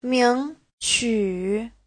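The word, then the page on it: 名曲: ming2qu3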